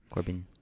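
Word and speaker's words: Corbijn: Anton Corbin. – Corbin.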